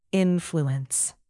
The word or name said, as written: influence